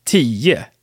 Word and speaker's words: tio: All right, so these were the numbers 1 through 10, and I just wanted to point out that we often pronounce nio and tio, 9 and 10, as nie and tie so that ending O turns into some kind of schwa, like unstressed thing.